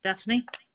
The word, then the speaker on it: Stephanie: Stephanie.